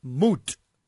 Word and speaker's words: muht: The word death in Aramaic is pronounced M-U-H-T. It's pronounced mut.